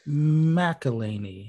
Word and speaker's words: McElhenney: It stars the team from It's Always Sunny in Philadelphia, including Rob McElhenney. Is that how his name is pronounced? – McElhenney? – Sure. McElhenney. Yeah.